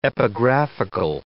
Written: epigraphical

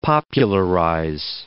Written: popularize

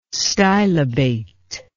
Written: stylobate

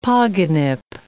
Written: pogonip

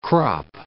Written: crop

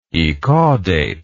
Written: ecaudate

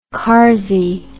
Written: carsey

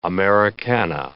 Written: Americana

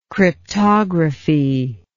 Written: cryptographal

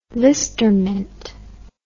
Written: listermint

